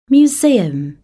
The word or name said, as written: museum